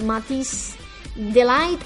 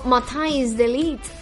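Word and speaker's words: Matthijs de Ligt: Esta vez fue a parar en manos de Matisse Delight o Matthijs Delight, si lo pronunciamos en holandés,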